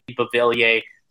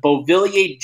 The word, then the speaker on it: Beauvillier: It's spelt like B-E-A-U-V-I-L-L-I-E-R. So he kept the bow, but the French version of bow, and then taped over it and wrote Horvat.